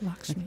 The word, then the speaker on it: Lakshmi: So, like Lakshmi. Mm-hmm. Am I saying that correctly? – Yes, Lakshmi.